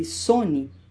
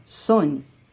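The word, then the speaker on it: Saône: O Sona, que os franceses chamam Saone, pronuncia-se Sone. O A é mudo nessa palavra, Sone.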